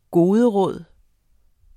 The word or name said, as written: goderåd